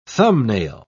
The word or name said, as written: thumbnail